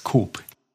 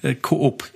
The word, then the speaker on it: Coop: Früher war es der Konsum, also ja, Coop ist schon in die Richtung, aber das zweite O wird nicht ausgesprochen. – Es gab es auch mal in Deutschland und da wurde halt es nicht so ausgesprochen, wie ihr es ausspricht, sondern hier wurde es Coop ausgesprochen.